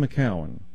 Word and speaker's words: McKeown: I think it's spelled M-C-K-E-O-W-N. Ian McCowan.